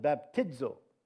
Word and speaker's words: baptizo: So the word baptize or baptism is the Greek word baptizo. Phonetically, you pronounce it baptizo.